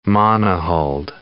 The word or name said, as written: monohulled